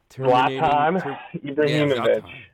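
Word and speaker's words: Zlatan Ibrahimović: Zlatan Ibrahimovic.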